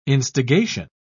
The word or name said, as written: instigation